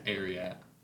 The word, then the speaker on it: Ararat: It's Ararat. You pronounce it Ararat.